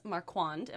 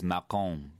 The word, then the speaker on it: Marquand: His name is Ross Marquand. Am I saying that correctly? – Well, yes. And okay. the French pronunciation is Marquand, but Marquand. That sounds very snobby.